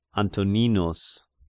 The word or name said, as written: Antoninus